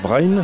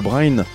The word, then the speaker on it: Bren: Quelques membres sont de Languidic, Brain, ça s'écrit B-R-E-N, Dei Ganin, viens avec moi, c'est le titre.